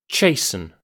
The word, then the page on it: chasten: British English